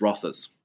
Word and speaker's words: Rothes: The- medieval um, Scottish word, so it's Rothas.